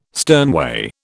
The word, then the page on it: sternway: British English